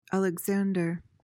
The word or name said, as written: alexander